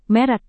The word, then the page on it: merek: Indonesian